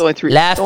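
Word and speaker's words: Lafrenière: Alexis Lafreniere. – Only three syllables, there you go. – Lafreniere. Oh, like you're laughing and you're running and you're gasping for some air.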